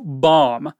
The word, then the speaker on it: bomb: E o inglês quer é ser chatinho e é, tem um B mudo. Então é só bomb.